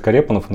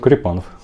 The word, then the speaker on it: Корепанов: Только Корепанов, наверное, потому что мне уже тут спрашивали, как ударение. Я так понимаю, что классическое – это Корепанов, но у меня родственники переехали на юг, и там как бы поменялось. Так что Корепанов.